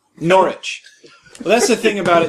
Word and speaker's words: Norwich: Like he lived in a town called that was spelt Norwich, but it's pronounced Norwich.